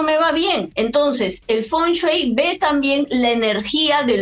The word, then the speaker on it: feng shui: Algunos dicen feng shui, ¿ya? – Pero no se pronuncia así, se llama feng shui.